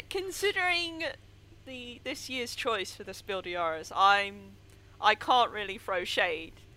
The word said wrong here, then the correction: Spiel des Jahres: Sorry, I was just thinking about how nobody ever pronounces the S in Spiel des Jahres.